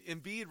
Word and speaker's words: Embiid: Embiid rhymes with indeed, but they didn't get it done.